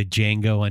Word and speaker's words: Django: like the Django Unchained line there, the D is silent, but there is no D.